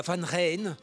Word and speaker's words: Van Rijn: Et puis il y a Van Rijn, la prononciation hollandaise, du Rhin.